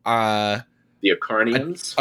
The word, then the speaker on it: Acharnians: And the two plays that you translate in this volume are, I hope I'm pronouncing these correctly, The Acharnians. – Acharnians, yeah, great.